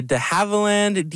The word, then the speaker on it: de Havilland: It is the de Havilland DHC-1 chipmunk.